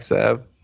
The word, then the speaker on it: salve: They probably used black salva. – Oh, no. Oh, no. I just saw the big toe. – No. – How many times you say that wrong? – Say what? – Black salve.